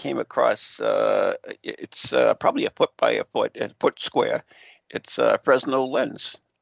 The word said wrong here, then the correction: Fresnel: Yeah, it's a silent SN. It's Fresnel.